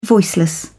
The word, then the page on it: voiceless: British English